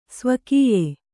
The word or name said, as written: ಸ್ವಕೀಯೆ